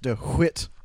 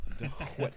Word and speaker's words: DeWitt: So, pronounced DeWitt. – DeWitt.